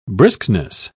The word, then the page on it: briskness: British English